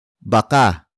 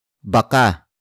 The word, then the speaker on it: baka: word for word it's baka, mabasag, mo, ang, salamin, baka, mabasag, mo, ang, salamin, baka, mabasag, mo, ang, salamin. The first word is baka baka